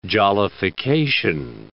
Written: jollification

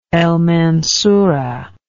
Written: El Mansura

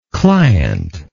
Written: clientship

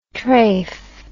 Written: treif

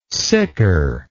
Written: siker

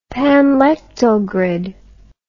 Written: panlectal grid

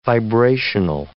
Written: vibrational

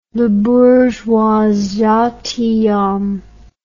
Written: Le Bourgeois Gentilhomme

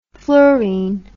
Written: fluorin

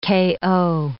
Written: KO

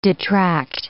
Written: detract

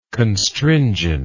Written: constringency

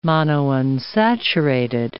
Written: monounsaturated

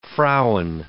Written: Frauen

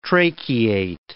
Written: tracheate

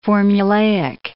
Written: formulaic

